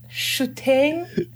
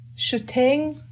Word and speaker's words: Shuteen: Way wrong, right? – Yeah, right. It's, almost. – No, it's not. Okay, go ahead. Say, please. – It's Shuteen. Shuteen Erdenebaatar.